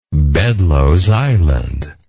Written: Bedloes Island

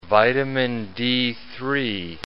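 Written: vitamin D3

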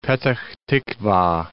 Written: Petah Tiqwa

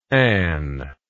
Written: Ann

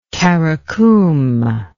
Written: Karakum

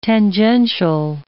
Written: tangential

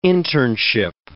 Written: internship

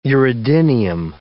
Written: uredinium

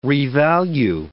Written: revalue

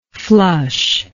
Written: flushness

